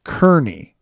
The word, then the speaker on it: Kearney: Anyway, you'll see his name spelled K-E-A-R-N-E-Y. And so you'll think it's Kearney because that's how it should be pronounced. But the way that name is usually pronounced when there's a lot of Irish in America is actually Kearney.